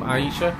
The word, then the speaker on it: Aisha: Is that how you pronounce that name? Aisha? – I can't see it. – A-I-S-H-A. – Yeah.